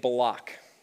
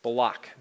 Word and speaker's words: Balak: So we meet this character named Balak. That's how I'm going to pronounce it, at least. Balak, B-A-L-A-K.